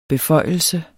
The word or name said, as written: beføjelse